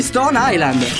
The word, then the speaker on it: Stone Island: Questi jeans della Stone Islands. – Stone Island. – Ma perché scrivono come si legge? – Si chiama così, Stone Island.